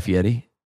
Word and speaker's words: Fieri: Fieri? Is it not how you say it? – Is that how you say it? – That's how you're supposed to say it.